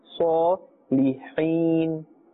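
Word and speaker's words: الصالحين: Saw is part of the Huruf Musta'aliyah, therefore it's pronounced with a full mouth. – Saw Lihin.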